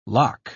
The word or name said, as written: lough